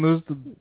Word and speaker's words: Newsted: Jason Newsted. – Good one. – She'd let him in her dry well. – Wait, how do you pronounce his last name? – Newsted. – Newsted.